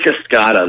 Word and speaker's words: Cascada: Is it Cascada? How do you pronounce that? – That's the Buick Cascada.